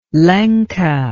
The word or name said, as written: lenca